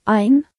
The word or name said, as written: 1